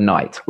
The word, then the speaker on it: night: So for example, the word night, like day and night. Why is it spelled that way? Why is there a G, H, and a T? Because you don't say those letters.